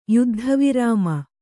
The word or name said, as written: ಯುದ್ಧವಿರಾಮ